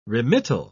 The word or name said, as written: remittal